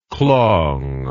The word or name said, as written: klong